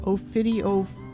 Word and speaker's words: ophidiophobia: Ophidiophobia. Ophobia. Ophidio. Ophidiophobia. – Ophidiophobia. – Yeah. – And ophidia, how do you spell that first part? – O-P-H-I-D, like an aphid, only it's an ophid.